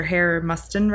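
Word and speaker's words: Hare-Mustin: And that's also part of what Dr. Hare Mustin writes.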